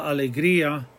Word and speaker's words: alegría: The word alegría, that IA, is two separate sounds. We put an accent on the I so that the I is heard equally as well as the A. La alegría, we're breaking up that diphthong.